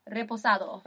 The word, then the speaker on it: reposado: It's a resposado. How do you say it? – Tequila reposado. – Reposado.